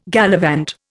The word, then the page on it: gallivant: Indian